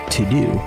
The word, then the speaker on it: Teuxdeux: It's called To-Do, and it's spelled T-E-U-X-D-E-U-X.